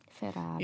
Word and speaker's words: Ferrari: Ferrari. – You have to say it like that, yeah.